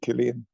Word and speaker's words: Cillian: Can I ask you to pronounce it Killian? – Killian, of course. I always say Cillian, but Killian Murphy is by far one of the best actors in the country, I think.